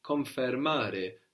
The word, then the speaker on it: confermare: In Italian is confermare. Now this is spelled with an I in English, confirm. But in Italian it becomes an E, which consequently makes it sound more like the English, no? Confermare.